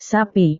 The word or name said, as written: sapi